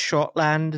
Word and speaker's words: Schottland: Like maybe, I don't know, a little old place called it's pronounced Schottland in German, known as.